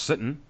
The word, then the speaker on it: Sitton: And you have Josh Sitton, or Josh Sitton, as we found out, that's how they pronounce his name, on the offensive line to help Laremy Tunsil, and Loganes actually made mention of that.